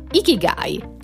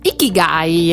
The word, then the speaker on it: Ikigai: E quindi questo nome giapponese, a quanto pare dai nostri studi fatti in questi due minuti, si pronuncia Ikigai. – Ikigai.